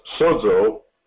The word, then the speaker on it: sozo: and it's pronounced sozo for the root here, but it says here, this is what the word means.